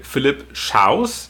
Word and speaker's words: Philipp Schaus: Neben Uwe Lulis ist jetzt auch, korrigiere mich gerne bei der Aussprache, Philipp Schaus, ist das richtig ausgesprochen? – Ja, ist richtig, dabei. Wie der deutsche Schaus sozusagen, genau.